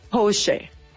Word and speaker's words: Porsche: Y solo como un dato cultural, déjenme les cuento que es alemana y su correcta pronunciación es, escuchen, Porsche. ¡Ándele!